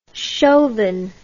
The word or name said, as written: chauvin